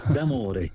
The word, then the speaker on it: D'Amore: Y entonces, mi apellido se pronuncia así como se lee, D'Amore, Bruno D'Amore.